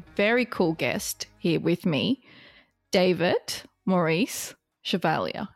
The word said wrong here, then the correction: Chevalier: Yeah, it's almost right. Chevalier, so it's a French name actually, and we are a French-based company, but I'm German.